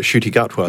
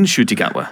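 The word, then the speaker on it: Ncuti Gatwa: Well, wasn't this the whole point that Shruti Gatwa said that he's been pronouncing his own name wrong his entire life because his mum recently corrected him? – Really? – Amazing. Yeah. I love that. – Because the N is not silent, apparently.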